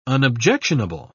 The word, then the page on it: unobjectionable: British English